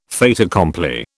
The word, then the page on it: fait accompli: British English